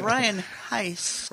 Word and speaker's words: Heiss: I have Ryan Heights is not his name. It is Ryan Heiss. – Rhymes with nice.